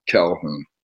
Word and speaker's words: Calhoun: J-I-M-I Calhoun, which is C-A-L-H-O-U-N.